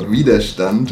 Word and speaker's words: Widerstand: Right, the track is called Widerstand, and that means resistance.